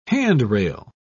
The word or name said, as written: handrail